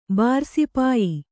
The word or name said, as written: ಬಾರುಸಿಪಾಯಿ